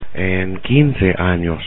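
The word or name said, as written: en 15 años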